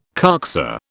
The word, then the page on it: coxa: British English